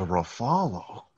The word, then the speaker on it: Ruffalo: The fuck is a Ruffalo? – I was like, I don't know. What's his name? He's like, it's Ruffalo. I was like, really? He said... It's literally spelled like buffalo with an R.